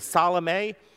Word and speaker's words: Salome: One, she's the wife of Zebedee and we believe her name is Salome. There are several ways to pronounce that, but that seems to be the most popular.